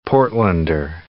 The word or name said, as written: Portlander